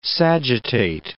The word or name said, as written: sagittate